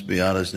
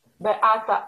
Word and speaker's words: Beata: Beata, a w domu mówiliśmy na nią Benia. – Polish pronunciation is Beata. At home, she was called Benia.